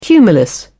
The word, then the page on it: cumulus: British English